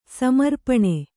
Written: ಸಮರ್ಪಣೆ

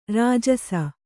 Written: ರಾಜಸ

